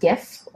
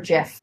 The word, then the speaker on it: GIF: Do you pronounce it GIF or JIF? I pronounce JIF.